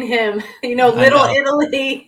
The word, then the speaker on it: Italy: It's not Italy, it's Italy.